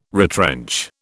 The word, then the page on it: retrench: British English